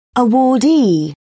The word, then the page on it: awardee: British English